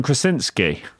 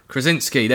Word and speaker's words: Krasinski: I have a terrible job at pronouncing his surname, John Krasinski. Have I done it there – John Krasinski. – Krasinski, there we go, sorry.